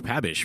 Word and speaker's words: Pabich: I typically tell people Pabich. – Okay. – ISH, but okay. I've also been told that it's pronounced Pabic. – Uh-huh. – The silent H.